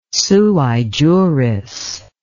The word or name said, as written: sui juris